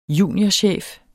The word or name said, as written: juniorchef